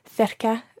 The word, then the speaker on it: cerca: Yeah, last week we learned how to say near to, cerca de, and I was wondering why the first C is pronounced th, but the second C is pronounced k. – Okay, that is a very good question. For our listeners, the word cerca is spelled C-E-R-C-A. So you've got a C at the beginning and then a C later in the word, and they're both pronounced differently. Let's deal with CA first of all. CA and indeed CO and CU in Spanish all have the hard C sounds.